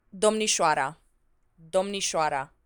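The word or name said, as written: domnișoara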